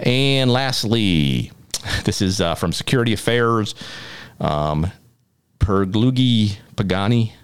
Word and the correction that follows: Paganini: I'm sorry, Paganini, I apologize to him or her for mispronouncing the name, but our headline here, National Security Council data theft.